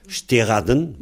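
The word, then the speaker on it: steredenn: Steraden. Bon, bah, c'est une façon de prononcer. Steraden. Alors, Steraden. Devant le D, la voix est longue, c'est l'air accentué, hein. Comme c'est l'avant-dernière syllabe.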